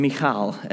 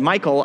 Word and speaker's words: Michal: But then there's Uzzah and Michal, as Mike so wonderfully pronounced in the Hebrew, and committed me then to saying it that way for the whole sermon. If you had said Michael, I could have just said that, but now I have to say Michal the whole time.